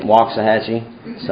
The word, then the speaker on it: Waxahachie: I grew up in Waxahachie, Texas. And yes, that's how you say it, Waxahachie.